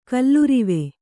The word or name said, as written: ಕಲ್ಲುರಿವೆ